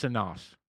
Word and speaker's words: Sonos: S-O-N-O-S. It's pronounced Sonos.